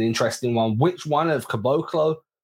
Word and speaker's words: Caboclo: Obviously, they've acquired the Celtics have acquired Noah Vonleh and Bruno Caboclo, and I went on to Basketball Reference to ensure that I pronounced that name correctly. K U H emphasis on the Bo. and then Klo, Caboclo.